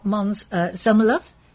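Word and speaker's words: Måns Zelmerlöw: So Mons, my son-in-law, Mons Zelmerlove, that's how it's pronounced.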